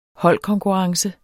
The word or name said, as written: holdkonkurrence